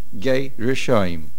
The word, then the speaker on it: Gei Rishoim: In Pasuk Gimel, the name is Gei Rishoim. There are two syllables in the name, Gei and Rishoim. Gei Rishoim.